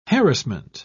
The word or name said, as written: harassment